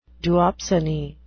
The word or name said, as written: duopsony